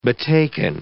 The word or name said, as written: betaken